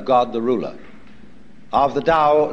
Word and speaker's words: Tao: That's pronounced Tao. If it had an apostrophe after it, it would be pronounced Tao. But Tao is more or less the right pronunciation for that word.